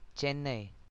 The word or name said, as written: Chennai